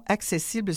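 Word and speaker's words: Axécible: Et vous êtes la conceptrice de la solution accessible. C'est bien comme ça qu'il faut prononcer parce que ça s'écrit AXECIBLE.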